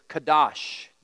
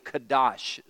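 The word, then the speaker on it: kadosh (קדוש): The Hebrew word for holy is the word kadosh. I know I'm using an H at the end, but that's the way you pronounce it.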